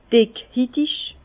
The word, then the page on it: dickhitig: Bas Rhin; Herrlisheim